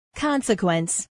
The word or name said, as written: consequence